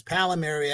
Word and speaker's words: Pallamary: And, yeah, and uh, it's no big deal, but my last name, uh, the best way to pronounce it is Pallamary, as in any Palomiri is a Palomine.